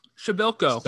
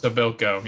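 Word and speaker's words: Przybyłko: What's the Shabilko? – Shabilko, yeah. – So look, here's the thing. Act like there's no P there or R. – Shabilko. – Shabilko.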